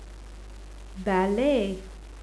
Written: ballet

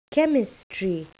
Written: chemistry

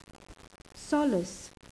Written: solace

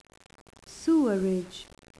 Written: sewerage

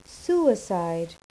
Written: suicide

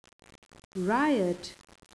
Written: riot

